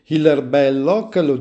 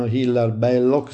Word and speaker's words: Hilaire Belloc: E infatti Hilaire Belloc, denominiamo, apostrofiamo un po' alla francese, molti lo chiamano Hilaire Belloc senza l'accento francese,